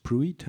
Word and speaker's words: Pruitt: Pruitt heißt er, also es richtig aussprechen.